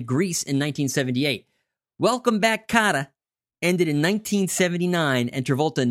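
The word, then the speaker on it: Kotter: He is tackling his first TV role since Welcome Back, Cotta. Did I say that right? – You did. Mr. Cotta.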